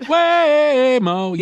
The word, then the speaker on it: Waymo: Waymo. Isn't that how you say it? – Waymo. Yes. Exactly.